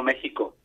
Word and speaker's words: México: La X, hay una cuestión ahí de pronunciación, sí. – Pero yo veo que aquí también se utiliza la J. Pero vamos, lo correcto siempre ha sido México desde hace mucho tiempo, ¿no? – Vale, México y Texas, de acuerdo, vale.